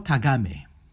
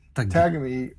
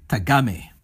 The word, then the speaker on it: Tagame: Gengoro Tagame. – Or are following with the Queer Japan documentary interviewing Tagami. – Tagame.